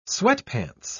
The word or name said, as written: sweatpants